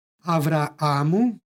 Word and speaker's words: Avraamu: U prethodnoj pak reči istoga stiha Avraamu izgovorili smo sa dva a, jer po Bibliji ime i tako glasi Avraam.